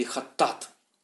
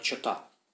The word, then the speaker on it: хаттат: В еврейском языке это слово «хата» или «хаттат», иногда произносится как «чата».